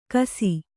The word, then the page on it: ಘೈಸ್ಸ: Kannada